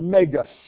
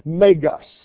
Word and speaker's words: megas: The word great is the Greek word megas. Sounds like the word mega. It is indeed the word mega, megas.